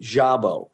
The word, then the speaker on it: Szabo: I don't know why I said Sabo. That's okay. Most people do. Actually, to be honest with you, my name is should be S-Z-A-B-O and should be pronounced Jabo. That's the way it's pronounced in Hungary.